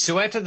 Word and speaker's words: Ceuta: Of it's Ceuta, isn't it? Is that right? – It's Ceuta. Ceuta. It's spelled C-E-U-T-A, but it's pronounced Ceuta in Spanish.